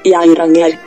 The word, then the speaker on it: Geiranger: Beh, è scritto Geiranger. Come si pronuncia? – Geiranger.